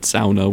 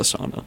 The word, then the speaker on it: sauna: He has a sauna in his house, and he is Finnish, so we have to pronounce it sauna whenever we talk about it. It's not a sauna.